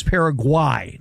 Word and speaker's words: Paraguay: So it's proper pronunciation is Paraguay, not Guay.